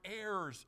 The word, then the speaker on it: heirs: No, we're heirs of God. You don't pronounce the first H, friend.